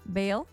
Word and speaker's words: Baal: Baal.